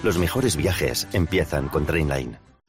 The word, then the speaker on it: Trainline: Se escribe mejor precio garantizado. Se pronuncia Trainline. Descarga la app de Trainline y ahorra comparando miles de trenes y tarifas.